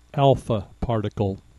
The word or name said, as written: alpha particle